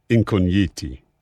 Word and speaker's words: Incogniti: It's easier to say Incogniti, which means comes from the word incognito and incogniti, but in Italian pronunciation, Incogniti.